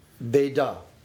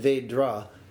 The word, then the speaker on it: Veda: Veda. – Veda. – Mm-hmm. And what you said when you said Vedra is very useful, because words morph and change as they move from one locale to another.